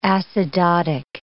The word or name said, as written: acidotic